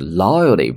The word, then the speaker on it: loyalty: 那么会员的计划呢，英文叫做 loyalty program，program 计划前面的 loyalty，注意啊，本来读 loyalty，但是呢，美式发音把后面的这个 t 读的，就是 loyalty program。